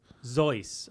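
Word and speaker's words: Zoës: Zoe's. – Okay. Zoe's. – Yeah. – That's rhymes with Rolls Royce.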